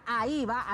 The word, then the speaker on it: AHIVA: Bueno, después de que usted ya anotó todos los gastos de esta semana, recuerde subir en la página de la AIVA, así se escribe, A-H-I-V-A, AIVA, la B alta, AIVA, AIVA, AIVA, usted así lo escribe, AIVA.